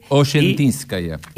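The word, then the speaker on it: Osetinskaya: La S, como si fuera SH. – Oshentiskaya.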